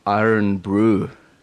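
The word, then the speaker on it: Irn-Bru: And on the label, it says Iron Brew. – Right. – How was the pronunciation? – That was very good.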